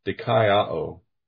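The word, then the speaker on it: dikaiō (δικαιόω): It's kind of hard to say, but if you write it in English, it's obviously a Greek word, but in English, it's D-I-K-A-I, and then a short O and a long O, dikaiō.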